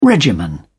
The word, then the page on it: regimen: British English